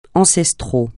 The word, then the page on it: ancestral: francais